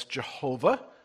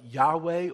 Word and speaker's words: Yahweh: Some people will mispronounce it as Jehovah. Nice try, but there's no hard J in Hebrew. It, a little closer is like Yahweh or Yehovah.